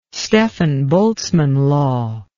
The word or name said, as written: stefan boltzmann law